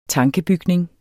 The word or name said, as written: tankebygning